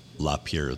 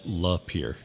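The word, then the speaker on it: Lapeer: It is not Lapierre, though. It's Lapierre.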